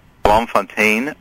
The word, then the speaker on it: Bloemfontein: The 1892 birthday of John Royal Ronald Tolkien in Bloemfontein, South Africa. – I'm so glad you said that because I wasn't sure how to pronounce that place in South Africa.